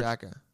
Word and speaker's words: DACA: Is it DACA action or for DACA? – They pronounce it DACA. It's not a real word. You can pronounce it however you want.